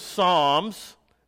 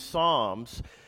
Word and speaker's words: Psalms: Now, the book of Psalms is spelled with a P. It's P-S.